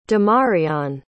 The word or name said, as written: demarion